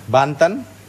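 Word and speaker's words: banten: Now, you pronounce it bantan? – Bantan.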